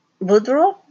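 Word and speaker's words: Boudreaux: Jessica Bordeaux is the lead singer. I'm sorry, I think I said that wrong. Oh, it's Boudreau? I'm sorry. I'm sorry, Jessica, I'm pronouncing that badly. But it's B-O-U-D-R-E-A-U-X.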